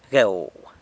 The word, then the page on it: gueu: sanlucasquiavini